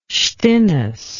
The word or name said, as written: stinnes